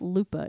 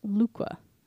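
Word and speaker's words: Luqa: He was a station manager in, it's not Lupa, it's Lukwa Airport in Malta. I've been saying it wrong this whole time.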